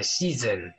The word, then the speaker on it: Shizen: sí Sí, algo parece, Shizen. Es el, tiene una Z como si fuera una abeja. Zen.